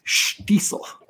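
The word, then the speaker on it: Shtisel: So the correct pronunciation of the show is Scheitzel.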